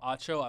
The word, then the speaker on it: Acho: And if you guys want to check out the video I'm talking about, go on Twitter, Emmanuel Acho. I'm hoping I'm pronouncing his last name correctly. – That's correct. Yeah, you are.